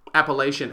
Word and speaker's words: Appalachian: Appalachian. Have you heard that word in your life? – I have. I can't remember where, though, but I have heard the word Appalachian.